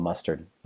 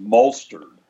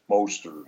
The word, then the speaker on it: Mostert: I just think of mustard, so I'm just going to call him Mustard. Raheem Mustard, right? Yeah. Like ketchup and mustard. But I believe it's pronounced Molster. – Oh, okay. – Molster. Mostert.